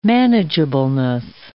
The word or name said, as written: manageableness